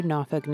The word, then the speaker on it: Norfolk: Matter of fact, residents had to teach me to pronounce it. It's spelled N-O-R-F-O-L-K, but I've been instructed to pronounce it Norfolk, though really people from different parts of the city say it different ways.